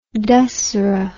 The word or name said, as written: Dassehra